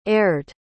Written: aired

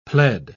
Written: pled

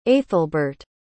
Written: aethelbert

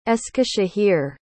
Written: eskisehir

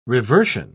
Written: reversion

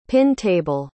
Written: pin-table

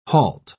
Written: halt

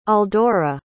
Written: aldora

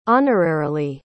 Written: honorarily

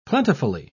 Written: plentifully

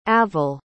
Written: avel

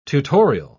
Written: tutorial

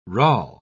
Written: rale

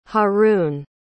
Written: haroon

